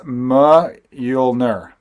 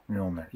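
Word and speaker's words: Mjölnir: Francisco Zimbrano has now said that it's pronounced Mjolnir. Mjolnir. Thor's hammer. That's what I thought it was. It's one of those things where whenever he says it in the movies, I go, I got to rewind it to hear exactly how he pronounces it. – I think it's Mjolnir. Am I right about that? – Mjolnir? No, Mjolnir sounds right. – Mjolnir, I think so. I don't know. – Mjolnir. Yeah, definitely.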